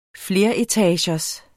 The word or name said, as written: fleretagers